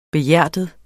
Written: behjertet

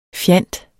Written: fjant

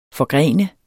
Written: forgrene

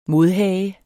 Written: modhage

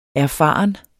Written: erfaren

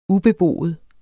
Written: ubeboet

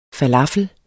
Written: falafel

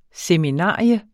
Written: seminarium

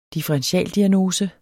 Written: differentialdiagnose

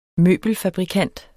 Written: møbelfabrikant

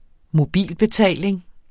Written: mobilbetaling

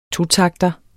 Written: totakter

